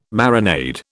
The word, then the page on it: marinade: British English